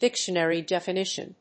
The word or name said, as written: dictionary definition